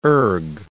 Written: erg

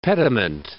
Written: pediment

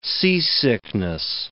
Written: seasickness

